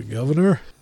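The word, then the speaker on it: Gov'ner: The Governor? – Okay. And, well, no, it's got the British spin on it. The Governor. Okay. So it's because it's spelled G-O-V apostrophe N-E-R.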